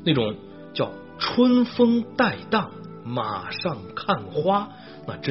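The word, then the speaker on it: 骀荡: 春风带荡，带荡的带，一个马字旁，一个台啊，台湾的台，带荡啊，就是很舒畅的意思了啊。这个带应该是个多音字哈，它还有一个音是台，就是指的是列等马。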